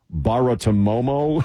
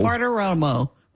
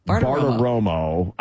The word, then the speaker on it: Bartiromo: Also, Maria Barta, I always get her name mixed up when I try to say it. It's so many syllables. Baratomomo, Romo, Baratomomo. – Bartiromo. – Bartiromo. I always have a problem with that.